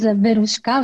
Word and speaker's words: Veruska: So my name is Veruska, which is a Russian name. So it's so hard to pronounce that people call me Veru, V-E-R-U, it's easy.